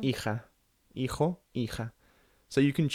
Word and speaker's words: hija: Hijo and hija.